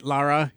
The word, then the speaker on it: Lara: Lara is how you pronounce it.